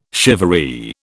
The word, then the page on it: shivaree: British English